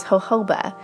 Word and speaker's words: jojoba: It's also made with, sorry, I had to think for a second how to say it, but it's jojoba, J-O-J-O-B-A, jojoba seed oil.